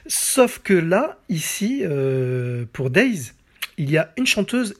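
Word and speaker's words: Daze: Alors Days, ça s'écrit D-A-Z-E, c'est un groupe danois qui euh, visuellement ressemble beaucoup au groupe Aqua.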